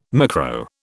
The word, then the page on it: micro: British English